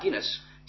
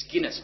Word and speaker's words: Guinness: And Howard Guinness, as many of you will know, it's Guinness, by the way, not Guinness. I've checked with his daughter. It's Guinness, that's how you pronounce it.